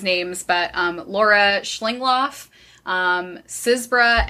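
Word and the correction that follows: Csibra: and Titone. – Chibra. – Zebra? – Chibra. – Chibra. Okay.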